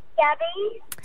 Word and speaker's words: Gabye: Yes, when they first hear it, the way it's spelled, they say Gaby or Gabe. – Oh, so how do you spell your name then? – G-A-B-Y-E.